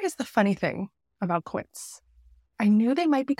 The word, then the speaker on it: Quince: Quince, it rhymes with Prince.